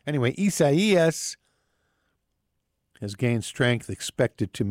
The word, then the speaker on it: Isaias: Isaias is how it's pronounced, which is kind of I don't know anybody named Isaias.